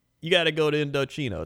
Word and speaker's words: Indochino: Indochino. That's right. Indochino. That's how you say it.